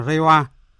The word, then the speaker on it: Reiwa: phiên âm tiếng Nhật đọc là Reiwa, Phóng viên Bùi Hùng, thường trú tại Nhật Bản phản ánh.